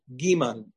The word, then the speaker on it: gimel: Temanim pronounce it differently, but according to the Sfaradim, our minhag, as brought down in Rav Pa'alim as well, gimel is with a dagesh is pronounced gimel. G. – Like a G. – Huh? Like a hard G.